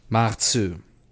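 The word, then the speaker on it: Maredsous: Mare Dussault. – No, you know what you said? You said Mare Dussault. – I was saying it, you know, Americanized. Okay. Maritsu. – Sounds like that.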